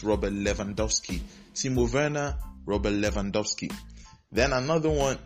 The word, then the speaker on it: Werner: And in German naming custom, anything that is W is pronounced as V. So it's Timo Werner, actually.